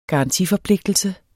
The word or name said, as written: garantiforpligtelse